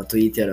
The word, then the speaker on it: Twitter: Twitter. – Mas tá... Twitter. – Ah, falei errado, ó, Twitter, olha aí, ó.